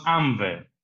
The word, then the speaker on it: ANVE: And it's called the ANVE. My Italian is, of course, not that that great. What's the? How do you pronounce it in Italian? – We pronounce ANVE. – ANVE, okay. And where does it stand for? – Associazione Nazionale Vivaisti Esportatori.